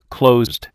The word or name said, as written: closed